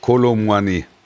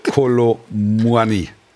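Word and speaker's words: Kolo Muani: Kolomwani. Kolomoni. Falsch. Kolomwani. Muani. Kolomuni. Nein, falsch.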